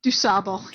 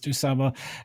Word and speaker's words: Du Sable: How do we pronounce his name? If you're in Chicago, it's Du Sable, yeah. – Yeah, I always learned it as Du Sable.